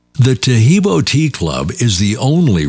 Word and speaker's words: Tahibo: Tahibo is spelled T-A-H-I-B-O.